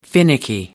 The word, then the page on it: finicky: American English